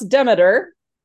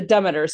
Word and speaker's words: Demeter: Demeter. Which is not how I was taught to pronounce Demeter.